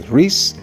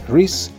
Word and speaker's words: Rhys: Así se llama, Trío de Guitarra, y anota su principal intérprete o creador, creo, es de Riz, con R-H-Y-S, Riz Chatham, con una H integrada y una M al final, Riz Chatham.